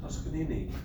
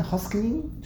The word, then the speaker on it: Toscanini: Toscanini. – What's his name? – Toscanini. – Toscanini? – Arturo Toscanini. – How do you say it again? Hoskinini? – Toscanini? – Toscanini? – Yeah.